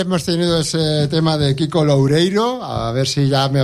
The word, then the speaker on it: Kiko Loureiro: Kiko Loureiro, ahora lo he dicho bien, joder, ahora macho.